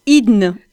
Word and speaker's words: hydne: Vous pouvez juste m'aider, alors comment ça se prononce, une hydne ? – Voilà, hydne.